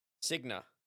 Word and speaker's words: Cigna: C-I-G-N-A. – Cigna.